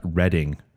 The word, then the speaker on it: Reading: Jim Smith lets us know that Reading is how it's pronounced instead of reading. – In Monopoly. Yeah. It's not Reading. It's Reading.